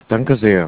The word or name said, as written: danke sehr